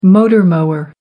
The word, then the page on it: motor mower: American English